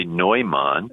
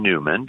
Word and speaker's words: Neumann: You know, the Bishop of Philadelphia in the early 19th century, and of course, if you see his name and you know German, you would say Neumann, yeah. Right? But he himself asked for people to pronounce his name Newman because he wanted to conform to the American speech patterns.